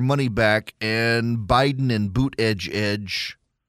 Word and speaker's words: Buttigieg: So the reason I say Buttigieg is because when he was running for president, that's what his campaign said, is that the way you pronounce his name is Buttigieg. It's Buttigieg, Pete Buttigieg.